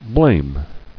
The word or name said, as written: Blamer